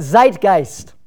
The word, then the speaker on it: Zeitgeist: And I asked Mrs. Pippert how you say that, because she had 2 years of German in college, and I didn't know how to say it. But Zeitgeist.